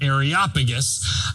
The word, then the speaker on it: Areopagus: And in verse 22, he's standing in this place called the Areopagus or Areopagus. That's how you say it, Areopagus, which is like this public square for reasoning.